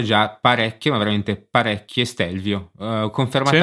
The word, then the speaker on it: Stelvio: Sì, no, eh, comunque la pronuncia è Stelvio.